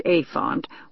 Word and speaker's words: APHONT: A-P-H-O-N-T, pronounced a font, was developed by the American Printing House specifically for low vision readers.